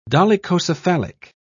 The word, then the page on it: dolichocephalic: British English